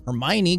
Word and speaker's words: Hermione: Hermione Granger. – Glad you said it, because I never knew how to pronounce it.